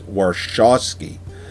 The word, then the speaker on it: Warshawski: So I looked it up on IMDb, and it's Warshawski. – Yeah. – And she even pronounces it that way in the movie.